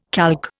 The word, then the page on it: calque: Indian